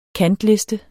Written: kantliste

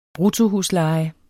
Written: bruttohusleje

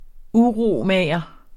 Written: uromager

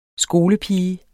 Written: SKOLEPIGE

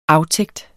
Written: aftægt

